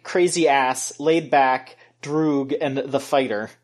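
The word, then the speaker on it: droog (друг): It looks like a peer, but it's pronounced droog.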